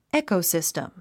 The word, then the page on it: ecosystem: ékousìstim; エコォシステム